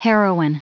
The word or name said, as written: heroine